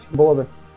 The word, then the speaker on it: Bode: ¿Se pronuncia Bode o cómo se pronunciará? Yo soy Bode. Muy malo. ¿Bode? – Bode. – Ok, entonces sí se pronuncia la E.